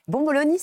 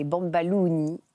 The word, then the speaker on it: bomboloni: Euh, bomboloni, ça veut dire euh, beignet en italien. Et chez les Tunisiens, prononcé avec l'accent tunisien, c'est les bambalouni.